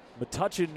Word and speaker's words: Metuchen: I've been told that I've been saying it wrong by a couple of New Jersey natives in our front office, so apologies to all of those folks back there. Metuchen, New Jersey.